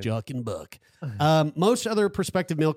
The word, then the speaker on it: Jochen: J-O-C-H-E-N-B-U-C-K. – Joachim. – Oh, Joaquin Buck. No, it's Jochen. It's probably Jochen.